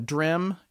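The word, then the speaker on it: Drem: So he's instructed to fly over this airfield in Edinburgh it was called the Drim Airfield, which I'm not pronouncing right, I'm sure, but it's D-R-E-M.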